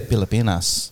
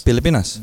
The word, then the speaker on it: Pilipinas: Bro, Philippines, the way those Filipinos say it, they say Pilipinas. – Pilipinas. – No H.